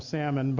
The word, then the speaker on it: Salmon: It just seems weird to call him Salmon, but it's pronounced that way as well.